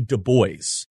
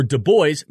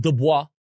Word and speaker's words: Du Bois: Washington wanted to cement the friendship between the races, where Du Bois, not Du Bois, he never liked that pronunciation.